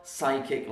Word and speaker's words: psychic: We spell psychic like this. And psychic actually has two silent letters. The P is silent and the H is silent because we don't say psychic, psychic. We say psychic.